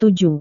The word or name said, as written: tujuh